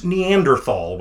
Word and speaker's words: Neanderthal: It is also, if anybody's interested, pronounced Neanderthal when you're speaking English.